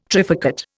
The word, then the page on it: trifurcate: Indian